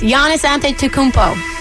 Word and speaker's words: Giannis Antetokounmpo: My takeaway is Giannis Antetokounmpo. Did I say it right again? – Yes.